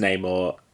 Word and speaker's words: Namor: Prince Namor, I think it's pronounced Namor, because I watched a YouTube video of Stan Lee saying it.